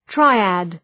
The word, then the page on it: triad: ‘traıæd